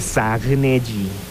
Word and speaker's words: Zagnelli: Zanielli se escribe Zagnelli, ¿no? Zagnelli. Está medio difícil, ¿no? Zagnelli, ¿no?